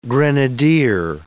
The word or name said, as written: grenadier